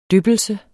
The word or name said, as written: dyppelse